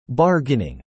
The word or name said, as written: bargaining